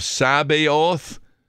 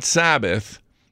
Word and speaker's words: Sabaoth: sometimes is referred to as the Lord of Sabaoth, not Sabbath, It's spelled S-A-B-A-O-T-H, and that is thought of referring to the angelic hosts that are pure in thought, the good angels.